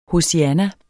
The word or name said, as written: hosianna